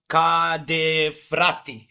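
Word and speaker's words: Ca' dei Frati: Lugana, in dem Fall haben wir vor uns stehen, also ich vor mir jetzt von Cadefratti. Genau. Ist das richtig ausgesprochen? Das hast du, glaube ich, richtig ausgesprochen.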